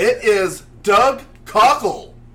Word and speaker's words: Cockle: Imagine Mr. Cockle coming his way. – It's spelled cock with an L-E at the end, so there's only one way to pronounce that.